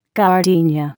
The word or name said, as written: gardenia